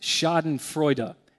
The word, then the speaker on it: Schadenfreude: I don't know if you've ever heard the term, I believe it's pronounced Schadenfreude. It looks like Schadenfreude to us.